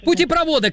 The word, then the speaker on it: путепровода: Совершенно верно, это сложное слово, образованное от двух других, а в таких, как известно, ударение всегда падает на второй корень, а он тут вот. Следовательно, правильно говорить еще раз как, Лев? – А? – Путепровода, короче говоря, да-да-да, я вас поздравляю, Лев.